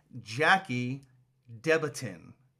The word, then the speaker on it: Jackie Debatin: That is Jackie Debatin.